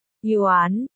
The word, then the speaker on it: Yuan: Hai chữ này đều phát âm là Yuan.